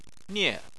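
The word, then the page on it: nie: tone 4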